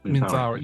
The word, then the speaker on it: Minthara: Is it Minthra? Is that how you say it? – Mm, Minthara.